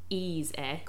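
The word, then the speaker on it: Eezer: The rather, I'm going to say interesting, Ease Air. Go and look up the spelling of that if you're confused about how I just pronounced it. It's E-E-Z-E-R.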